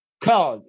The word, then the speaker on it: Köln: Köln. In Germany, you speak it Köln. It's pronounced in Germany Köln. Yeah, and the foreign people say Cologne.